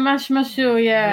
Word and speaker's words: Mashmashu: Nordic Warriors by Mashmashu Studios. Is that right? Is that how you pronounce it? – Yeah, Mashmashu, We're basically just two people, so...